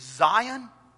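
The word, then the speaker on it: Zayin: There's Zion, that's how it's pronounced.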